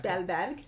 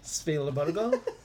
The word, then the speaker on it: Spielberg: Spielberg. – Spielberg.